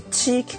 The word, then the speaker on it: Qi: iPhone の方は基本的には、地位規格を使うということだそうですね。 QI と書いて地位と読むというあれですけどもね。